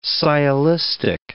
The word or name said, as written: sciolistic